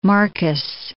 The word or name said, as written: Marcus